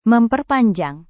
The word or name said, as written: memperpanjang